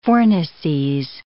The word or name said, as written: fornices